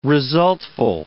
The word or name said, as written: resultful